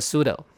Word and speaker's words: pseudo: 好，拆解开来，前面那一块叫 pseudo，看得出来吧，两个音节叫 pseudo， P 不发音哦。你们听过江南大叔叫 Psy 对不对？他绝对不是念 Psy，好，或者是变成吉普赛人吉普赛，太难听了。所以这个字念 pseudo， pseudo 你翻字典， pseudo name 就是假名，假的化名，像这个就是假名化名艺名。